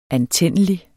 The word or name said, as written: antændelig